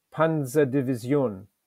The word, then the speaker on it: Panzerdivision: It should be pronounced as nasally as possible, with a soft S and the stress on the final syllable, Panzerdivision.